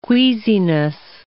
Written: queasiness

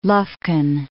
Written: Lufkin

